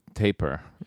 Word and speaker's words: tapir: After the zoo visit in San Diego, I'd have to say the tapir. – Yeah, is it tapir or tapir? I'm going to say it wrong. – Tapir, like, like Kashmir? – Yes, it's a Led Zeppelin classic, tapir.